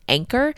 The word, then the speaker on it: Anker: So this is by, I believe you pronounce the company's name, Anker. It's A-N-K-E-R.